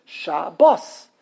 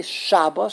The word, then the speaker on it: Shabbos: The last syllable is bos. So it's shabos. Good shabos. Good G-O-D-D-K in English.